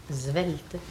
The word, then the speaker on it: svelte: Bon, une prononciation correcte, svelte, n'est-ce pas? – Ah, svelte, oui, disent. – Oui, svelte. Les gens instruits du midi, qui connaissent le mot et qui l'emploient, qu'est-ce qu'ils prononcent? Svelte. – Ah oui. – Bon, il est svelte.